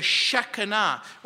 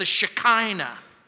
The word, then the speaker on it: Shekinah: The glory is the Shekinah, or as some people mispronounce it, the Shekinah.